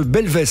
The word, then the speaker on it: Belvès: Noël Dequesne avec nous, vous êtes la propriétaire du château de Belvès, c'est bien comme ça qu'on prononce hein? – Tout à fait, c'est bien comme cela.